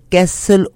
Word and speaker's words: castle: बेशुमार पार्किंग है कैसल ओक 225 कैसल ओक क्रॉसिंग में. कैसल CASSEL कैसल ओक क्रॉसिंग इन ब्रैम्पटन. ठीक है, CASTLE कैसल CASTLE कैसल ओक क्रॉसिंग इन ब्रैम्पटन. कैसल T silent है ना.